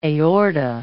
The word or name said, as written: aorta